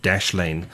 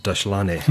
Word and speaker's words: Dashlane: It's called Dashlane, Dashlane, and not as I sometimes pronounce it, Dashlane, as a South African.